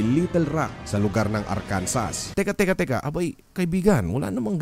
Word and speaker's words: Arkansas: Bawal mo ding bigkasin ang Arkansas with S ang Arkansas kahit na ang palatitikan nito ay A-R-K-A-N-S-A-S. Ang basa dito ay Arkansas.